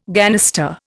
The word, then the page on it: ganister: Indian